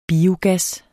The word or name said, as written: biogas